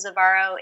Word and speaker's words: Zavaro: You can either friend me on my personal page or you can follow me on my professional page, Jaclyn Zavaro Intuitive Guide. And I'll just spell my last name for you guys. It's spelled Zavaro.